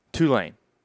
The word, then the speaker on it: Tulane: I just have to say, it's Tulane. Yeah. – Tulane. – Yeah. No. – What did I say? You've been saying Tulane. – Yeah. Tulane. – The emphasis is on the two.